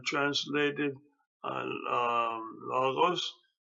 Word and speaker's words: logos: People say logos. It's all right, but the better trans, uh, pronunciation is logos because there's two different O's uh, in that word. One is omicron and one is omega. So it's logos, not uh, uh logos, not logos.